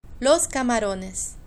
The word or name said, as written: los camarones